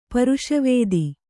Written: ಪೌರುಷವಾದಿ

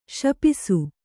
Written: ಶಪಿಸು

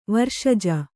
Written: ವರ್ಷಜ

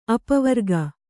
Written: ಅಪವರಕ

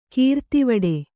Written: ಕಿರಾತವಡೆ

